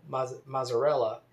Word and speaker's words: Mazzarella: Mayor Dean Mazzarella. It's spelled M-A-Z-Z-A-R-E-L-L-A. Like mozzarella, but with an A instead of an O. So I think he doesn't pronounce it Mazzarella because it sounds like the, like mozzarella.